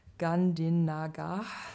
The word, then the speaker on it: Gandhinagar: und mit dem äh, PAS oder besser mit ihm und seiner Heimatuniversität oder dem Heimatinstitut in Gandhinagar. Ich hoffe, ich habe es richtig ausgesprochen. Er macht das gleich nochmal richtig, hoffentlich. Ja. Mer merken diesen Namen bitte. – Ja, Gandhinagar. Äh, ja, mit denen planen wir ein ähnliches Projekt wie das Nourish-Projekt.